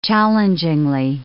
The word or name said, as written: challengingly